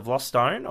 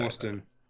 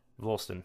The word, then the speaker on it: Vlastuin: Uh, how do you actually pronounce it? Is it Vlostone or Vlosten or how would you say? Vlosten. Vlosten. – There you Vlosten.